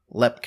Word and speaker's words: Leap: And talk about one of the most, I will say, talked about haunted locations in the entire world, Lepp Castle. – Like L-E-P? – Well, no. Like, very much like. It's spelled L-E-A-P. – Oh, okay. – But they don't pronounce it leap.